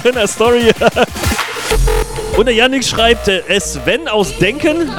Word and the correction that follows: Denkte: Habe ich das falsch ausgesprochen? Nee, Denkte. Groß Denkte.